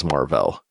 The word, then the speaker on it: Mar-Vell: And the fact that it's, you know, increasingly weird to just have to force yourself to say Marvel instead of Marvel with it all. And it's also kind of fun as a thing to say is Marvel, because it is spelled that way. It's MAR-VELL.